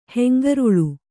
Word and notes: ಹೆಂಗರುಳು: hengaruḷu

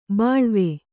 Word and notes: ಬಾಳುವೆ: Kannada

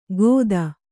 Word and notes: ಖೋತ: khōta

